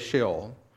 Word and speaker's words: Sheol: It's a transliteration of a Hebrew word, which means they just simply spelled it in English letters, but the word is Sheol. And that's how it's pronounced in Hebrew as well.